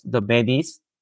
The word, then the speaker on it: Métis: My owner was the Métis. Am I right to pronounce the Métis? – The Métis, the indigenous people.